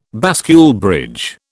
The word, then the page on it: bascule bridge: British English